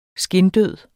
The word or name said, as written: skindød